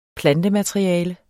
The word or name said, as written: PLANTEMATERIALE